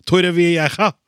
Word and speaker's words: Torrevieja: Torrevieja må man som sørlending legge helt om til rulle-r-er for å få uttale det korrekt.